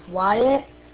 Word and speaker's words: Wyatt: Roger, my name is Wyatt, spelled Whiskey, Yankee, Alpha, Tango, Tango.